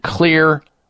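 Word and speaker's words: Xlear: And when you look at the ingredient list in Clear Rescue Nasal Spray, and by the way, Clear is spelled with an X, X-L-E-A-R, Clear Rescue Nasal Spray.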